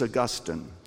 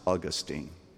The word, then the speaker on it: Augustine: His name was Augustine, sometimes pronounced Augustine.